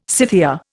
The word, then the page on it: Scythia: Indian